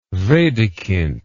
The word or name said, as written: wedekind